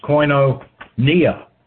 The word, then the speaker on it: koinonia: And that word fellowship is koinonia, K-O-I-N, long O-N-I-A.